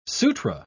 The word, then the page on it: sutra: British English